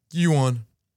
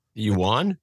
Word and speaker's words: yuan: dollar moving up against to let's see if I can say it like they say it in China. They taught me how to say this in China. Yuan. You got to say it with a lot of nasal.